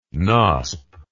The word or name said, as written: knosp